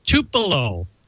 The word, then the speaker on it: Tupelo: Don't forget, October 22, Tupelo. Don, did I say that right? Tupelo, Mississippi, Papa yes.